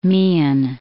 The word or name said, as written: Meighen